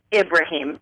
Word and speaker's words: Ibrahim: My last name is Ibrahim.